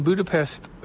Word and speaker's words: Budapest: But holy moly, I mean, we were just in Budapest, it's the proper way to say it, Hungary.